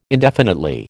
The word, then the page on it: indefinitely: American English